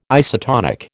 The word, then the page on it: isotonic: American English